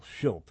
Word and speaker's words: Skillt: And we talked about a Swedish Nazi sniper called Michael Schilt. I'm going to pronounce it that way, S-K-I-L-L-T.